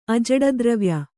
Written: ಅಜಡದ್ರವ್ಯ

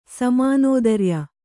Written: ಸಮಾನೋದರ್ಯ

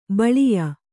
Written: ಬಾಲೇಯ

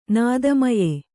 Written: ನಾದಮಯೆ

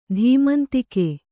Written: ಧೀಮಂತಿಕೆ

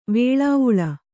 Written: ವೆಲ್ಲವಾಲೆ

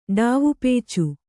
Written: ಡಾವಪೇಚು

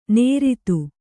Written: ನೇರಿತು

